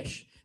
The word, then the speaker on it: Geis: G-E-I-S. I think it's Gesh. Gesh. It's pronounced okay. Gesh.